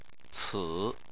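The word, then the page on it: ci3: tone 3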